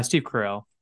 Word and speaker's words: Carell: Steve Carell.